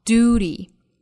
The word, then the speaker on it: duty: 在美音中一般不加这个 y 的发音，读成 duty without pain 尾部的 t 略掉， pain 双元音 a 加鼻音 n 的组合要注意。